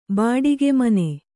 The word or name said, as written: ಬಾಡಿಗೆಮನೆ